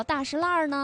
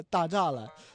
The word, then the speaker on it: 大栅栏: 哎呀，一说这个大栅栏的话呢，他们就很不理解，为什么当地人叫大石烂呢？这两个有没有什么关联呢？ – 其实说到大石烂的读音，就是本地人都叫大石烂，然后其实它就根本就不是字面上那个大栅栏，就是这也是老北京话的一个魅力的体现的地方，就是一是儿化音，然后二是方言的一种演变。